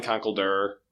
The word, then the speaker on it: Conkeldurr: topic for this episode is you know how do you pronounce that fighting type with concrete pillars? It's definitely Conkeldurr,